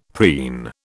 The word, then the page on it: preen: British English